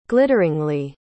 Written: glitteringly

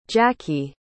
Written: jacki

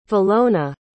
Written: valona

